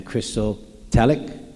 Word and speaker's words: Christotelic: Christocentric and Christotelic. Did I say that correctly? Yep. A couple of things.